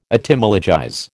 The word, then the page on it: Etymologize: American English